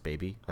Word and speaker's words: BB: They're actually called BB, like letter B, letter B, but it's pronounced baby.